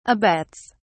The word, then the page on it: abets: British English